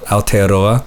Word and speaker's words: Aotearoa: I don't even know how to pronounce it. Yeah, Aotearoa, which is the Maori language. It's how they say, you know, the country in Maori.